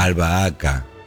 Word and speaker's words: albahaca: ¿Y cómo se pronuncia? – Albahaca. – No, nadie dice al bar. Sí. – Porque se respeta el tiempo de la H animal. – La H intervocálica.